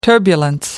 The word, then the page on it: turbulence: American English